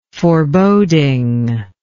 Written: forebodingness

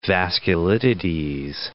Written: vasculitides

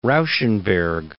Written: Rauschenberg

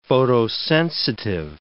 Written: photosensitive

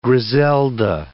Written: Griselda

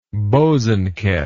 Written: bosanquet

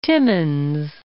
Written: Timmins

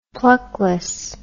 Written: pluckless

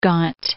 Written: John of Gaunt

